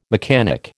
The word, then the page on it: mechanic: American English